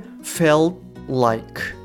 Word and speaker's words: felt like: t があの落ちちゃいますなので felt like